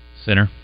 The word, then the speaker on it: Sinner: How do you say that in Italian? – Sinner.